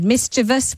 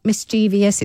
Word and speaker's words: mischievous: All right, let's revert to this conversation about the word mischievous, which is how you pronounce it. It is not mischievous. It does not rhyme with previous.